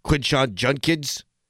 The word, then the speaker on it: Quinshon Judkins: Quinshon Judkins? Did I say that right? Yes, I did.